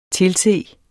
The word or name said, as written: tilse